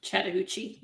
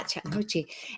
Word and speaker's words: Chattahoochee: Did I say that right, I hope? – Chattahoochee, yeah. – Yeah, Chattahoochee.